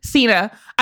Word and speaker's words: Cena: How do you pronounce his name? – Cena.